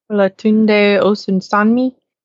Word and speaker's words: Olatunde Osunsanmi: Olatunde Osunsanmi? – Yes.